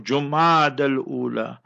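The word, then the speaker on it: Jumada al-Ula: It is Jumada with the Dhamma Pesh on the Jim. Jumadal Ula. So if the crescent is sighted, that will usher in the month of Jumadal Ula.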